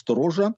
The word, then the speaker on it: сторожа: Есть такое слово «стража», ну, оно произносится «сторожа», то есть слово «сторож», «сторожа».